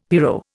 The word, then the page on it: pyro: Indian